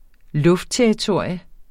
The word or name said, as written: luftterritorium